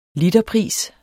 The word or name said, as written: literpris